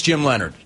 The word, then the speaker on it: Leonhard: There are a lot of names that are not pronounced the way they are spelled, including Jim Leonhard.